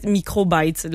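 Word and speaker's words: Microbytes (µBytes): C'est littéralement un biscuit imprimé 3D d'une compagnie, ça s'écrit U-Bytes, mais c'est en fait Microbytes, le nom.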